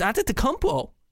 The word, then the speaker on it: Antetokounmpo: But it's Antetokounmpo, guys.